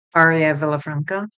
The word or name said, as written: Aria Villafranca